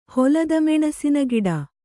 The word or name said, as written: ಹೊಲದಮೆಣಸಿನಗಿಡ